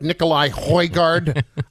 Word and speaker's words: Nikolaj Højgaard: If I could have a last name with a silent J and back-to-back A's like Nikolaj Højgaard, I would be very happy with that, who is currently 5 under.